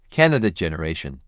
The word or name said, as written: Candidate generation